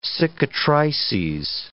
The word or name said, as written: cicatrices